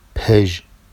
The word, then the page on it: peʒ: Vafsi